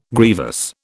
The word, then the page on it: grievous: British English